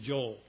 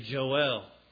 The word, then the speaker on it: Joel: And so with that in mind, when you read Joel, I'm using our modern pronunciation of the word. You know, you meet someone, what's your name? My name's Joel. But it's actually more correct to say Joel.